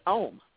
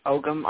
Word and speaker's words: Ogham: I first learned Ohm, which is some people pronounce it Ogham. I've also heard it pronounced Oyam.